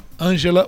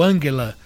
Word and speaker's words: Angela: Angela ou Angela, na pronúncia alemã,